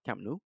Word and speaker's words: Camp Nou: This is the famous Camp, was it Camp Nou? Camp Nou? – Yep. Not a bad pronunciation for you.